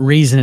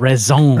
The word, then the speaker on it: raison: No, it's not reason. It's raison.